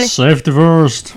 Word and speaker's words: Safety First: Safety First.